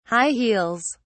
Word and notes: (high) heels: British English

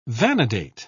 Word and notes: vanadate: British English

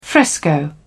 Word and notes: fresco: British English

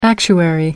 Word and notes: actuary: American English

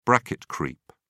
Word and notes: bracket creep: British English

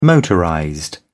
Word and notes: motorized: British English

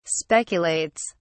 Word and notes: speculates: British English

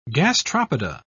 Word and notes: gastropoda: British English

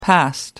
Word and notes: past: American English

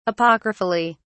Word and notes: apocryphally: British English

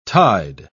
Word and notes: tied: British English

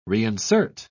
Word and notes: reinsert: British English

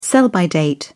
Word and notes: sell-by date: British English

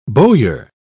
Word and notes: bowyer: British English

